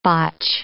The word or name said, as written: botch